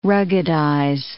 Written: ruggedize

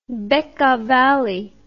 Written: Bekka Valley

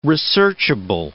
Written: researchable

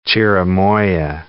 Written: chirimoya

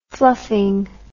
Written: fluffing

